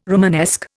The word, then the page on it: Romanesque: Indian